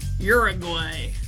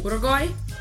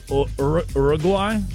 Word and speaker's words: Uruguay: Uruguay. Had to say it like an American. – Yeah. Can you say it as a non-American? – Well, it doesn't start with a your sound. – Oh. – Uruguay? – Uruguay?